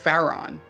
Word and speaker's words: Faron: It's, I believe it's pronounced Faron, F-A-R-O-N. – F-A-R-O-N.